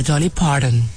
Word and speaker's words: Dolly Parton: ¿cómo se dice Dolly Parton? – No, señor, Dolly Parton.